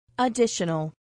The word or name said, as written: additional